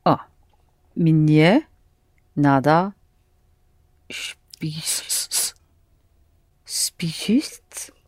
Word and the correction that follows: спешить: The first letter is S, С, and in the end you have Ш. Спешить.